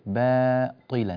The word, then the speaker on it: باطلا: باطلا، لا رقق الباء والألف، باطلا باطلا.